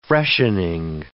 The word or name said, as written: freshening